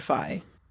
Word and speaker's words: Scourgify: S-K-U-R, all caps, is in the pronunciation. – Scourgify. That's the way he says it in the video game, in Harry Potter and the Chamber of Secrets on the PlayStation 2.